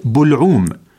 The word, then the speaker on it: بلعوم: مستمعينا الكرام، من بين الكلمات التي يكثر الخطأ في تلفظها كلمة بلعوم، وجمعها بلاعيم بضم الباء لا بفتحها كما يشيع في لغتنا المسموعة والمقروءة. – فالفصيح بالضم لا بالفتح، وذلك لأن بلعوم جاءت على وزن فعلول مثل حلقوم التي وردت في القرآن الكريم في قوله تعالى حتى إذا بلغت الحلقوم.